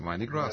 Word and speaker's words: Mardi Gras: Mardi Gras.